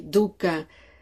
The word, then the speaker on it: dukkha: Ahora, veamos el significado de dukkha, esta palabra en pali, dukkha, que se escribe D-U-K-K-H-A, dukkha, doble K y luego H, dukkha.